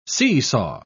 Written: seesaw

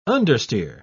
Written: understeer

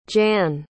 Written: Jann

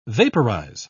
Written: vaporize

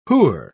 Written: who're

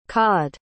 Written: cawed

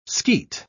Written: skeet

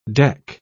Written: deck

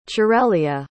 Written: cerelia

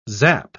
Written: zap

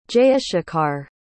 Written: jayashekhar

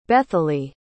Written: Betheli

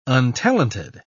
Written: untalented

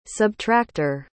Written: subtracter

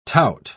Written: tout

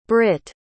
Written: britte

